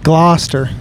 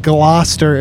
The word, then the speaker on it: Gloucester: Then there's a lot more vowels and consonants than it would be that I would think Gloucester is spelled. – Yeah, you can knock the U out of there, the C, one of the E's.